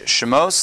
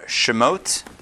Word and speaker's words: Shemos: This week, we start a brand new book, Chumash Shemos, the book of Exodus, or Shemot, depending on your Havara preference, how you like to pronounce it.